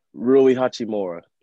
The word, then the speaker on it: Rui Hachimura: Rui Hachimura. – Rui Hachimura.